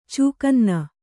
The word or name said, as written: ಚೂಕನ್ನ